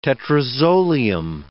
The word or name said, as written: tetrazolium